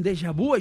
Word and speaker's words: déjà vu: Déjà vu. Déjà vu. Vu. Amb una V labiodental i amb una I labialitzada. És a dir, perquè la U sona I labialitzada en francès.